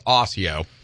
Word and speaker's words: Osseo: Mandy Galt of Leslie and Anna Martin of Osseo. It's pronounced Osseo.